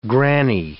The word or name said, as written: granny